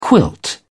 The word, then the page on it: quilt: British English